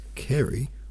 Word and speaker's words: Carew: Thomas Carew, pronounced as Carey, was among the Cavalier group of Caroline poets.